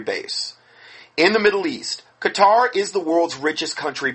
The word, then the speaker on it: Qatar: He pronounces it Qatar. Yeah, Qatar. No, it's Qatar, okay? It's not pronounced that way. It's Qatar. It's Q-A-T-A-R.